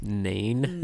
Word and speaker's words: Nain: So he goes to this, Jesus goes to this town called Nain. Is that how you say it? – Why can I not see this town's name right now? Yes, Nain.